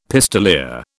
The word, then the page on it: pistoleer: British English